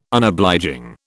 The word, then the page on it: Unobliging: British English